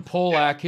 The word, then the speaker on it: Polak: In fact, Dallas Stars defenseman Roman, am I saying this correctly, guys? Polak? Is that what it is? – Yeah. – Roman Polak,